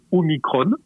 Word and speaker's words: Omicron: Il faut dire Omicron pour une raison simple, expliquée. C'est comme epsilon. Voilà. En grec, quand un mot se termine par on, on prononce on, Omicron.